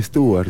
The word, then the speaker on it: Stewart: ¿Stewart? ¿Cómo se dice? – Sí, Stewart.